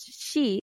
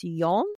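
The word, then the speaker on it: shi (四): I don't think I've ever seen the fourth floor be excluded, but they've definitely used the other word for 4 because there's shi, which is 4, and then there's 7, which is shichi. But 4 can also be pronounced as yon, and 7 can be pronounced as nana.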